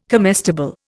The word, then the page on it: comestible: Indian